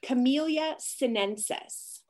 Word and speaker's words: Camellia sinensis: And you mentioned, how did you say it? Camellias? – Camellia sinensis.